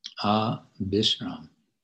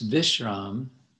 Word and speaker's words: Abhisram: Okay, the B in the transliteration here. In Sanskrit, it would be a V. But in Bengali, B and V are basically the same, pronounced as B. So it's Vishram. In Sanskrit, and then the opposite, Abhisram, Bengali, Abhisram.